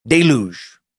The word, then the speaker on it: deluge: In these accounts, the Abrahamic gods sent the great deluge, the biblical flood, deluge, is how it's usually pronounced,